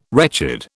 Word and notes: wretched: British English

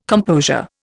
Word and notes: composure: Indian

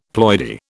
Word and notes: ploidy: British English

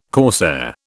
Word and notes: corsair: British English

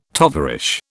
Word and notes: tovarish: British English

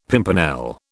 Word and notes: pimpernel: British English

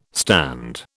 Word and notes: stand: British English

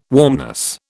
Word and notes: warmness: British English

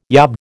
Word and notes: yob: American English

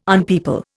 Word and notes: unpeople: Indian

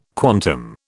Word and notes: quantum: British English

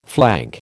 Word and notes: flank: American English

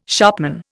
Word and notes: shopman: Indian

